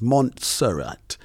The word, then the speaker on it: Montserrat: Not many people pronounce it correctly. It's Montserrat. Left there many years ago.